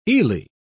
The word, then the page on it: eely: British English